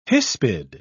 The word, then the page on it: hispid: British English